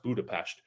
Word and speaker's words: Budapest: Although I will note, in the Avengers, they call it Budapest. And then we have a scene in this movie where Scarlett Johansson explicitly explains to someone it's pronounced Budapest.